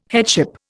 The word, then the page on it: headship: Indian